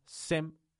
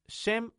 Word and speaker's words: Shem: El mayor era Sem, Shem o Sem. Ah, depende de la pronunciación, depende del idioma, depende de la, también la versión que tengas de tu Biblia.